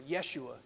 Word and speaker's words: Yeshua: So Jesus is the Greek form of the Hebrew name Joshua, or Yeshua, it would be pronounced.